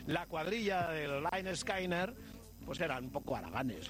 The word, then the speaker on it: Lynyrd Skynyrd: El disco se titulaba Pronunciad Lynyrd Skynyrd. Somos los Lynyrd Skynyrd. ¿Por qué somos los Lynyrd Skynyrd? Mira, existía un profesor de gimnasia que se llamaba Leonard Skinner.